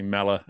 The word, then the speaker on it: Mellor: And it's just coach_Steve_M for Mellor. And I get to say my name properly on this show. It's nice. The Americans put this weird or on the end of my name.